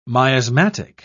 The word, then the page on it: miasmatic: British English